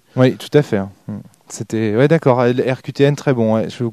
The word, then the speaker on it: RQTN: Donc, ça s'écrit RQTN, comme les lettres, RQTN.